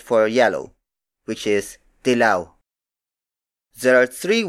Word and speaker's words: dilaw: dilaw dilaw Dilaw.